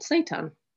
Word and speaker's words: seitan: So I started researching meat alternatives, how to make your own, and came across this thing called seitan, which is spelled S-E-I-T-A-N. And it's made Seitan? Like, like seitan? – Yeah, it's not the same, no, I know.